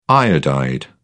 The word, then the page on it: iodide: British English